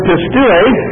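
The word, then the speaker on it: pistuo (πιστεύω): I hate to hear that they now pronounce this pistevo in Greece. I don't know if that has anything to do with our turncoat American Greek that's back there in charge of things, but nevertheless, they pronounce it pistevo. It's the old Greek pronunciation, of course, is pistuo.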